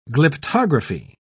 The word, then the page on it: glyptography: British English